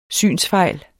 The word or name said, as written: synsfejl